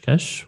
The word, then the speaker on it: Cache: Cash. What's the best way to pronounce it? – Oh, I call it cash, like money.